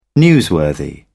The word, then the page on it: newsworthy: British English